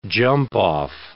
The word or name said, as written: jump-off